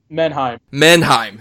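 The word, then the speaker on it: Menahem: It is written and directed by Mahamam Golem. I apologize. – Menheim. – Thank you.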